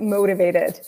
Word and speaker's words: motivated: Motivated. Mo, O as in go, mo.